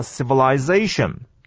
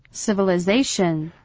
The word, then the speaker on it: civilization: 我们来跟各位复习一下，这个念作 civilization。 – civilization 这个 civili 好、啊，有人念 i，有人念 a，是轻音的，是重音的都无妨。